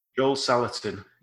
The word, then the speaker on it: Joel Salatin: Joel Salatin, yeah.